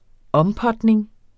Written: ompotning

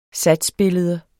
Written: satsbillede

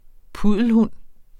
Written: puddelhund

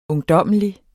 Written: ungdommelig

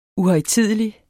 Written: uhøjtidelig

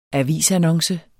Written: avisannonce

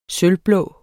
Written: sølvblå

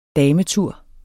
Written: dametur

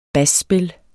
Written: basspil

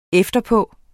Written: efterpå

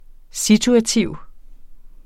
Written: situativ

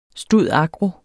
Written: stud.agro.

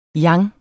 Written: yang

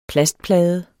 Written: plastplade

